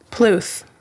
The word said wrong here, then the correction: Puth: Noel. Puth. – Puth. – There you go.